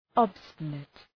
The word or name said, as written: obstinate